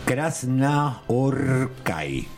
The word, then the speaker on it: Krasznahorkai: Krasznahorkai. – Así es. – Ese es el nombre de este señor. – Vas a ver cómo se pronuncia realmente. – Sí. Bueno, porque encima es Kras, SZ, Na, H-O-R-K-A-I.